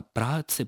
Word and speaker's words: праотцы: То есть мы говорим «прадеды», «правнуки», и, соответственно, «праотцы» попадают в эту же парадигму, и ударение должно сохраняться на этом же слове.